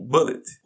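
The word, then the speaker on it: Bulleit: Um nome em que a pronúncia é bem parecida com bullet, ou bala, ou munição de pistola.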